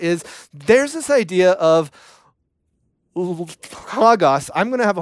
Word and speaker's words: Logos: This idea of L-O-G-O-S. How you say it? Logos. Thank you.